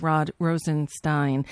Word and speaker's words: Rosenstein: That's how you say it, Stein, not Steen. It's Frankenstein, but it's Rod Rosenstein.